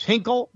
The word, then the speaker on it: Hinkle: Yes, Hinkle rhymes with tinkle.